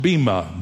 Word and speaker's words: bema: First of all, the word judgment seat is the translation of one little Greek word, which is pronounced bema, B-E-M-A.